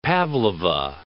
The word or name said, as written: pavlova